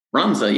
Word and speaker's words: Runza: Runza, yeah.